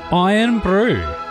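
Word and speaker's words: Irn-Bru: Iron Brew. – I think that's the way to go. That's how they say it in Scotland.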